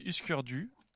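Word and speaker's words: Hüsker Dü: Après, on va écouter Husker Du.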